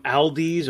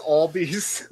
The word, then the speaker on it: Aldi: It's Aldi's, or Aldi's. Sorry, is that the? – It's not a dude. It's not Aldi's. – That's the Staten Island pronunciation.